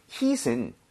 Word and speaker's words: heat: 그리고 발음 주의하셔야 되는 게 heat은 이렇게 치다 할때 HIT죠. hit. – 아, 그건 다르죠. – 다릅니다. HEAT는 중간에 모음이 두개 끼면서 입모양이 좀 벌려져야 돼요. 오케이, 그래서 그냥 힘을 빼고 hit.